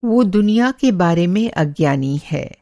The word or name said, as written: अज्ञानी